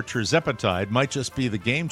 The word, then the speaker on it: tirzepatide: I have been pronouncing it as tirzepatide, when in fact, a listener with ADD was nice to admit they have ADD and informed me that it's tirzepatide.